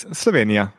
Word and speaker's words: Slovenia: Please, I think I'm not pronouncing Slovenia properly. How do you say it? – It's Slovenia. It's fine. – It's fine? Okay. – Yeah, it's fine.